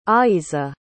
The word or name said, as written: aizza